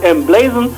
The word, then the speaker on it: emblazon: Also man kennt das Wort vielleicht nicht so wirklich, weil es im englischen Sprachgebrauch nicht so häufig benutzt wird, aber geschrieben wird es M-Blazon. So würde man es aussprechen, wenn man es lesen würde, aber englisch ausgesprochen halt M-Blazon und steht für ausgeschmückt oder schmücken, feierlich verzieren, so wie wir unsere Musik auch verstehen, ne?